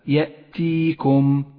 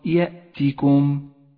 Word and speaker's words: يأتيكم: انتبه أخي القارئ إلى كلمة يأتيكم، يأتيكم، واحذر من إشباع كسرة حرف التاء حتى لا تصير ياء، فتقول هكذا يأتيكم، هذا خطأ شائع، والصواب يأتيكم، يأتيكم.